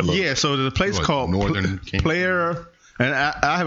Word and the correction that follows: Playa: It's Playa. – Playa, thank you.